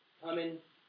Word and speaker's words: cumin: You give a tenth of your spices, mint, dill, cumin, how do you say that? Cookers? Cumin, thank you.